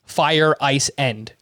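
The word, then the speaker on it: Feyereisen: and it literally sounded like Fire Ice End.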